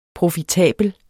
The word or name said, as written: profitabel